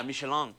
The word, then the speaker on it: Michelin: Michelin.